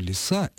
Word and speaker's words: лиса: Обратите внимание на то, что лиса имеет ударение на окончании.